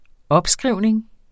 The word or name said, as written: opskrivning